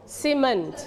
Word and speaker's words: cement: We stretch on the second syllable พยางค์ที่สอง cement